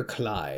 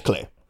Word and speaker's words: Klei: or Clyde? It's Klei. Klei. Rhymes with play.